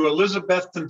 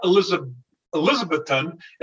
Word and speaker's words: Elizabethton: Central Minnesota to Elizabethton, Tennessee. Now, it's not Elizabethton, it's Elizabethton. And the locals will tell you that's how you pronounce it.